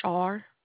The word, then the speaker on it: Char: My name is Char.